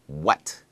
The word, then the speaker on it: what: Maybe you remember a few lessons ago, we looked at the WH words where, when, and why, and we discovered that the H is silent. Well, it's true in what also. Really, we only pronounce the W.